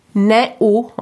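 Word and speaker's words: não: Le O tilde se prononce on, et le A avec un tilde se prononce on, un. C'est ça On en déduit immédiatement que naon se prononce an. Néo, en fait.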